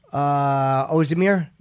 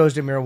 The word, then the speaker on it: Ozdemir: Ozdemir? – Yeah. – Ooh, big boy with a memory. I thought Ozdemir won.